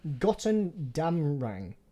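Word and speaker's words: Götterdämmerung: It's from Gotterdammerung. – Gotterdammerung.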